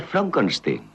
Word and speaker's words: Frankenstein: ¿Me toma el pelo? No, se pronuncia Frankenstein.